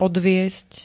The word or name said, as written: odviesť